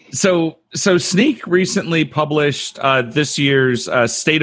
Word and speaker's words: Snyk: It is Sneak. And Sneak. – And how do you spell that? – Oh, yeah. – S-N-Y-K, which is why everybody gets it wrong.